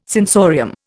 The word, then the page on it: sensorium: Indian